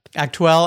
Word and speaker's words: Aktuell: Sam has contributed to many magazines also, including Motorsport, Race Car Engineering, Motorsport, Aktuell. Did I say that right? Aktuell? – That's how I say it. My German's not great, but Aktuell and the official FIA magazine.